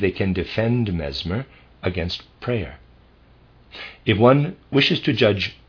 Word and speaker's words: Preyer: I think his name is probably pronounced Preyer, P-R-E-Y-E-R, I'm going to say Preyer. Preyer then took up the works of Mesmer with complete goodwill and could find nothing in them but empty words.